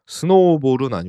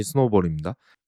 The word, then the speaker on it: SNOBOL: 이 스노볼, S-N-O-B-O-L, 스노우볼은 아니고요. 그냥 발음이 스노볼입니다.